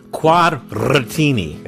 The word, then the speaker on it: Quadratini: Say it like you're Italian. – Quadratini. – There you have to roll the R's. – Quadratini. – Quadratini. – You gotta go teeny.